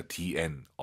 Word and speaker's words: 点: 재밌는 건 이 얼화를 빼면 디엔입니다. 디엔. 얼화를 집어넣으면 DR이고요. 바뀝니다. 발음이. 디엔. DR. 꼭 기억하세요.